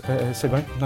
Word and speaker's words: segue: Very good Sieg, nice segue, nice one Jonathan. Spoilt slightly by pronouncing it Sieg first, never mind.